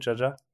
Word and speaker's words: Jaja: Now, this new record of yours, Jaja, is it, do you pronounce it Jaja? – Yeah. That's how it sounds.